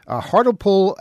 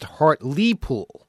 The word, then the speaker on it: Hartlepool: I was saying Hartlepool because it's spelled H-A-R-T-L-E-P-O-O-L. Hartlepool, as mentioned on last night's Off the Wall, is pronounced Hartlepool. As if there were two E's there. Hartlepool. So pronouncing the E was almost right.